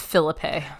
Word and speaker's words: Phillippe: I've pronounced his name like 6 different ways so far. – It's Phillippe. – It's Phillippe? – Sure, right? It's Ryan Phillippe. – I think so. I feel like I've heard him say it that way, so that's probably the way to go.